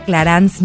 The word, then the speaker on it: Clarins: Hoy tenemos a dos girl bosses, dos de las mujeres que más admiro en la industria, Karla Ortiz, directora general de Clarance México, y Emiliana Rodríguez, Digital and Public Relations Manager de Clarance.